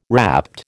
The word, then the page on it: rapt: American English